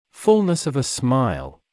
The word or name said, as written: fullness of a smile